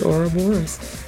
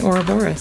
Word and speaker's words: Ouroboros: So all of you are saying it correctly by pronouncing it Ouroboros.